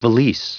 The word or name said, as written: valise